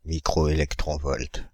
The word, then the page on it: microélectronvolt: /mi.kʁo.e.lɛk.tʁɔ̃.vɔlt/; France (Île-de-France)